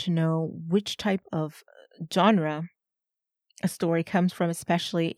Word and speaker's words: genre: Genre. Let me spell that. G-E-N-R-E. Genre. This is a word from French that we use in English.